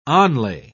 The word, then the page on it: onlay: British English